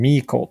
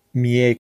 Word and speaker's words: Mieko: They're calling her Mieko, but it's Mieko.